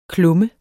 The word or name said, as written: klummer